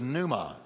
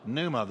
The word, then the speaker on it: pneuma: The Greek word for spirit is pneuma. Pneuma, the P is silent.